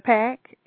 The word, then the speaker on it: Sister Pack: Now, am I saying it right, Sister Pack? – That's correct. It is Sister Pack. – I wasn't sure if it was like Pac or... – No, it is Pack.